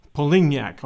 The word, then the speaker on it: Polignac: You had an interesting story here, Sam, regarding Major General Polignac.